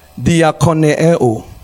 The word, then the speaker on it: diakoneo: The word serve here, and it is a Greek word that's pronounced diakoneo.